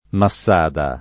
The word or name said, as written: Massada